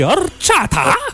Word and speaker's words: Horchata: Je prononce pas assez. Horchata! – Horchata. Horchata. Tu peux le dire ouais, comme ça, avec le petit accent et tout.